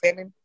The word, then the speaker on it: salmon: You put S-A-M-M-O-N. It's salmon. Salmon. – I just have to mentally take the L out. Like you don't pronounce it though.